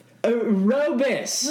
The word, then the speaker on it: rooibos: It is pronounced aerobis.